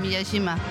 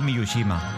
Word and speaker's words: Miyajima: Miyajima. ¿Lo he dicho bien, Nina? – Lo has dicho bien, Miyajima. – Es que yo tenía la manía de decir Miyushima. Es Miyajima.